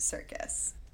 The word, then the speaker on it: Serkis: I enjoy that his last name is spelt Serkis and it's pronounced Circus. – I would have never thought that Circus was S-E-R.